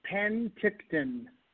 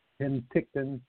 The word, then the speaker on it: Penticton: how do I say that, the name of the city in Canada? – It's Penticton. – Penticton, Canada. – Penticton, British Columbia, Canada, yeah. Good job.